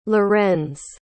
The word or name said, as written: lorenz